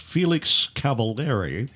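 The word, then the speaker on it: Felix Cavaliere: Singer, songwriter, and keyboardist Felix Cavallari. Yeah. I guess that's how you pronounce it. – It is.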